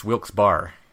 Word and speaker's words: Wilkes-Barre: How's it pronounced there? – It's Wilkes-Barre. – Wilkes-Barre, right? Because that makes sense.